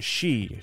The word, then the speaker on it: Xi: Chinese, apparently it's pronounced Xi.